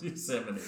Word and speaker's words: Yosemite: Pronounced Yosemite. – Yeah. – I'm going to say Yosemite.